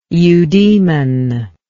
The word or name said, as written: eudemon